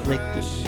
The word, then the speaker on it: Liechty: I've got a friend named Matt Liechty."